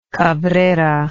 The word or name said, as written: Cabrera